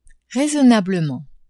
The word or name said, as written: raisonnablement